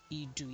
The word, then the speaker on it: Idu: The train station is located in a village known as Idu. I hope I get the pronunciation correct. Idu is I-D-U, Idu, in Abuja. And it is, Idu is about 30 minutes from Abuja, from...